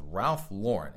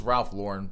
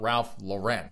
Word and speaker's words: Ralph Lauren: Justin Thomas has lost a sponsorship with Ralph Lauren. And it is Ralph Lauren, folks. It is not Ralph Lauren. So just stop mispronouncing that poor man's name.